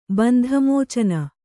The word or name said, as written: ಬಂಧಮೋಚನ